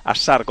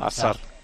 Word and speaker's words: Hazard: en Bélgica por lo menos le llaman Hazard, con la S así como un poco muy suave, ¿no? Hazard. Uh-huh. Lo pronuncian en Bélgica, sí, porque le he preguntado.